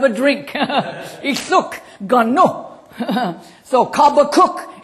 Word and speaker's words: Habakkuk: Habakkuk is the way the Hebrews pronounce it.